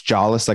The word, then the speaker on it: Jolles: It's jawless. Like I don't have a jaw.